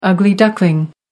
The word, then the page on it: ugly duckling: American English